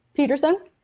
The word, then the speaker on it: Peterson: So this is made by Annabelle DeBetton Pearson. Peterson? Why do I always say Pearson? I always like skip a syllable on her name.